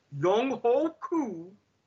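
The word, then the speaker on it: Younghoe Koo: And I honestly don't think they're going to change the rule, but I think what you're going to do is, and yes, this is the proper pronunciation of his name. I don't give a damn what you networks try to say to keep this up. Young-ho Koo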